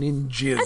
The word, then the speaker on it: Ninjzz: There's no I in it, but there's really no other way to pronounce it other than ninjas. – And that's how they pronounce it in the show. I mean, yes, it's written out N-I-N-J-Z-Z, but it's pronounced ninjas.